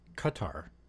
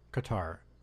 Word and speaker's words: Qatar: And, oh, wait, Actually, apparently it's pronounced Qatar, like cutter, Qatar.